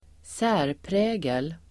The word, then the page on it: särprägel: [²s'ä:rprä:gel]